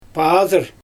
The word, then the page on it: paDyr: Xyzyl